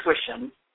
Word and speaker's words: Wishom: It's Wishom.